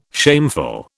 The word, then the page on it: shameful: British English